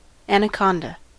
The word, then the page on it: anaconda: American English